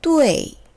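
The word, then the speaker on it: 뚜에이 (对): 뚜에 이 D U E 표기에는 e가 생략돼서 d, u, i로 쓰여 있어요. 뚜에이 사성이고요.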